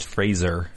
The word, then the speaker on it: Fraser: Since we're going to get yelled at, it's Fraser. Fraser. You pronounce that with a Z.